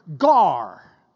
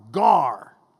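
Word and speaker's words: gar (γάρ): It starts out with this Greek word gar. Not the fish, but it's spelled the same way. It's gamma, alpha, rho, G-A-R, gar.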